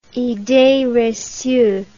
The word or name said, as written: idee recue